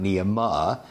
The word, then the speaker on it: Nehemiah: The ayah is pronounced. It's not Nehemiah or it's not Nehemiah. It's Nehemiah. Each vowel gets a full hit of the pronunciation.